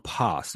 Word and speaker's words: pas: The word things is the Greek noun pas, that's spelled P-A-S, mm-hmm.